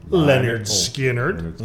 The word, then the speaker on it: Lynyrd Skynyrd: It's by Lynyrd Skynyrd, and it's their debut album from 1973 called, pronounced Lynyrd Skynyrd.